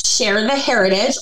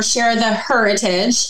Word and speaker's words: Share the HERitage: And mine is Share the Heritage, or Share the Heritage. It's pronounced either way, capital H-E-R for women.